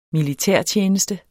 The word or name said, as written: militærtjeneste